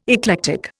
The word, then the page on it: eclectic: Indian